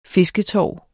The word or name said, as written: fisketorv